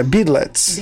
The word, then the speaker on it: Beadlets: Eu falei Beatles, mas está errada. É, Beadlets.